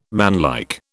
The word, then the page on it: manlike: British English